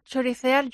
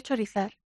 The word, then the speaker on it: chorizar: La palabra choricear yo siempre la he pronunciado. – Sí. – Y es más fácil decir chorizar. – Tú decías choricear. – Sí. – Ah, y estabas equivocándote. – Y me estaba equivocando.